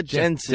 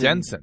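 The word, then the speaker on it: Janssen: Pronounce her name, though, is it? – Famke Johnson. – Famke Jensen. – Jensen. There you go. – Jansen, Jensen.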